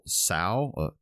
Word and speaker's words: Tso: that it's pronounced General Tsao, to Tsao or something like that. Anyway, I'll say General Tso because that's how it's pronounced usually.